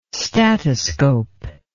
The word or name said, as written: statoscope